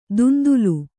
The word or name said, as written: ದುಂದುಲು